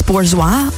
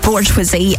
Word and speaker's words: Bourgeois: But now we've got the Throttle House guys alongside Francis Bourgeois, which I think is how you pronounce his second name. I keep going to say Bourgeoisie, and that's definitely not it, but it's spelt like that.